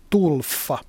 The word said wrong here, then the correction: Tolfa: Tulfa. Tolfa, anteeksi. Niin se vissiin lausutaan, t o l f Tolfa joka sijaitsee siis noin suunnilleen siinä Civitavecchian kohdalla.